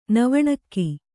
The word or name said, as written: ನವಣಕ್ಕಿ